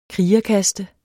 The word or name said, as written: krigerkaste